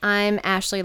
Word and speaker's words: Ashleigh: I'm Ashleigh Lockerbie. My name is spelled kind of funny. It's L-E-I-G-H, like A-S-H-L-E-I-G-H.